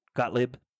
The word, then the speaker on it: Gottlieb: Gottlieb? Did I say that right? Gottlieb? – Yep.